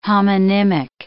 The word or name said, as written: homonymic